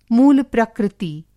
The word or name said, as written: मूल-प्रकृति